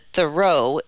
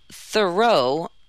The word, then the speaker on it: Thoreau: There is consensus that Thoreau is the correct way to pronounce it. John J. Kusich, co-president of the nonprofit Thoreau Alliance, said in an interview, "But somehow, Thoreau," here Kusich uttered the word the common way, "got into the culture, and it's in the water to pronounce it that way."